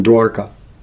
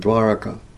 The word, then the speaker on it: Dwaraka: In Dwarka. Let's say it right on Dwaraka. Dwaraka. Otherwise you speak Hindi, Dwarka.